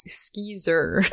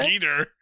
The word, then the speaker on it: Skeezer: Is it S-K-E-E-T-E-R? – Yeah. That's what I was just about to say, is you would probably be able to find it if I pronounced it correctly. It's Skeezer. – Oh, Skeezer. – S-K-E-E-Z-E-R.